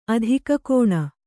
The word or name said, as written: ಅಧಿಕಕೋಣ